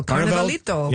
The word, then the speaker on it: Carnavalito: So Carnavalito, I'm mispronouncing it. I apologize. – No, you know, it's beautiful. Carnavalito.